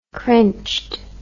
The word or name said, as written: crinched